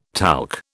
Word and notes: talc: British English

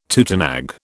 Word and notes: tutenag: British English

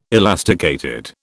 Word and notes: elasticated: British English

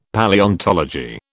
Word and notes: Palaeontology: British English

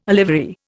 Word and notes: olivary: Indian

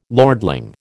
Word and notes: lordling: American English

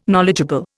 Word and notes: knowledgeable: Indian

